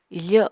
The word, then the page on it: ifo: apatani